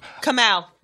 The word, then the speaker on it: Kamau: That's Kamau Bell. – Kamau Bell. Whatever. Shut up. How do you say his name again? – Kamau. – Kamau. Ring it.